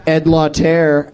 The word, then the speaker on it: Ed Lauter: Ed Lauter, and I'm pronouncing it right.